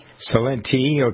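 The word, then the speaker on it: Celente: Salente, okay. With an emphasis on the E.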